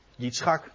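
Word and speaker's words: Yitzchak (יצחק): En het is trouwens mooi, want dan lees je Abraham, hij lachte, maar hier staat in het Hebreeuws Yitzchak. En dat is precies de naam van de jongen die geboren werd. Yitzchak, dat is de Hebreeuwse uitspraak, de Hebreeuwse spelling, dat is Isaac.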